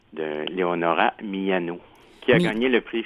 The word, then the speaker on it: Miano: Miano, M-I-A-N-O, donc vraiment comme ça se prononce, et Leonora avec un A, oui.